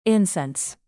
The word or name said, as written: incense